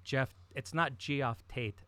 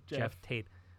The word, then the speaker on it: Geoff: But the way that he spells his name is G-E-O-F-F. So I always grew up calling him Geoff Tate. I've been corrected a million times. It's not Geoff Tate. It's Jeff Tate. But again, I can't stop pronouncing it Geoff Tate.